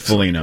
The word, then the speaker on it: Foligno: So it's a silent G. – I think Foligno.